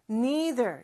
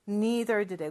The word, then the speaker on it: neither: 나도 싫어라고 할 때는 neither 요 단어를 쓰죠. 그런데 발음은 neither도 되고 neither도 된다고 했어요.